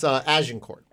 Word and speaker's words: Agincourt: Tell me about the Agincourt soldier and tell me if I'm pronouncing that correctly. – You're not, but I wasn't going to say anything. – Correct me. That's fine. – It's Agincourt.